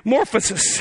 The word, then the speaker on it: morphosis: But if I were to try to pronounce this word, I found it. It's morphosis, right? Kind of like metamorphosis.